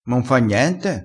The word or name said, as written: m'importa